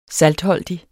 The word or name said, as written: saltholdig